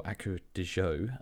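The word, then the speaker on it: Acqua di Giò: I used to wear, or Acqua di Gio, as it's supposedly pronounced,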